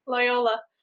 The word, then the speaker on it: Loyola: and a BA in Fine Arts from Loyola. Am I saying that right? – Loyola.